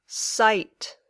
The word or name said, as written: site